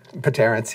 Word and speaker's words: Paterrence: Do they both start with P? – Paterrence, yeah. – Paterrence. Yes, they do. The P is silent.